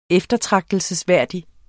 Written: eftertragtelsesværdig

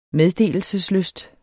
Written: meddelelseslyst

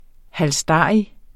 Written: halsstarrig

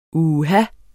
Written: uha

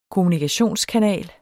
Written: kommunikationskanal

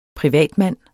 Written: privatmand

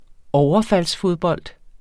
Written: overfaldsfodbold

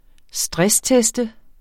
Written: stressteste

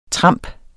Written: tramp

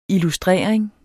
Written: illustrering

